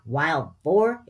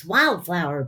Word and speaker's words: wildflower: What's wild for? – Sweepy asks. Glory takes the big sister tone. – Not wild for. It's wildflower, bee. Wildflower.